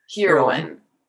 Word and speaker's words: heroine: Maybe you should make the E a long E. – Heroin. – That will sound better.